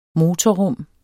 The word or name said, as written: motorrum